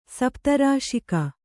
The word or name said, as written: ಸಪ್ತರಾಶಿಕ